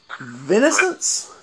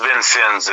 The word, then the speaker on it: Vincennes: He plugs a house show in Vincennes, Indiana. – It's actually pronounced Vincennes, Indiana. – Okay. – Lance was saying it all kinds of weird.